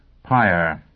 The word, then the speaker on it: Pyre: Pyre. Capital P. Y-R. Capital E. Pronounced Pyre. As in funeral pyre.